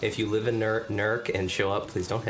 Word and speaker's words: Newark: Or if you're from there, NERC. – NERC. – That's how they pronounce it.